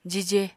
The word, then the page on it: jije: apatani